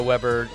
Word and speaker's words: Weber: I'm Joe Weber, does jokes on Instagram. That's Weber with 1B, like the grill, not the basketball player.